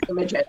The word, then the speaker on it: Imogen: Because anytime he talks about you, it sounds like Amy Jean. And I'm like, how do I say her name? – Frankie pronounces everything a little funny. my name is Imogen.